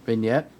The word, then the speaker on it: vignette: Vignette. – Okay, vignette. I was like, when I went to sound that out, it just sounded a little racist. – The G is silent, motherfucker.